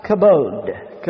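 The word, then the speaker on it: kabod (כָּבוֹד): It comes from a Hebrew word that is pronounced kabod. Kabod.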